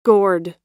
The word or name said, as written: gored